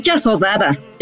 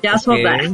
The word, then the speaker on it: Yasodhara: Es Yasodhara. Yasodhara. Okay. Perfecto. Se escribe Yasodhara, pero es Yasodhara.